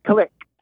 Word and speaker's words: Kalik: Yeah, the pronunciation on this horse is a stickler for the connection, so it's Kalik.